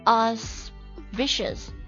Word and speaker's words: auspicious: 如果没有的话那就听我讲了这个单词的话呢读作 auspicious 有点像那个 suspicious